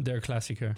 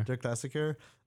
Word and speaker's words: Der Klassiker: Der Klassiker. – Der Klassiker.